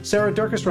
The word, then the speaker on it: Durkis: Sarah Durkis, Rhymes with Circus,